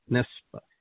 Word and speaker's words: Nebsa: Her name is Sylvia Nespa. How do you say that? – It's Nespa. It's phonetic. N-E-B-S-A.